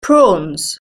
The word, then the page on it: prawns: British English